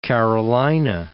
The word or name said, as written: Carolina